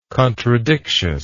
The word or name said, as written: contradictiousness